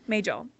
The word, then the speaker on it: Majel: It is the only original series episode to prominently feature Nurse Christine Chapel, who was played by, Majel Barrett. I don't know if I said her name right. – Majel.